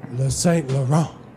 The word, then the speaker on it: Saint Laurent: Yeah, but I looked up how to say the word in French, so I knew how to properly say it. It's Le Saint Laurent.